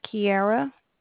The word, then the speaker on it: Kiara: Is Kiara. – Kiara. How do you spell that? – K-I-A-R-A. – K-I-A-R-A.